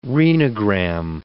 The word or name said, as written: renogram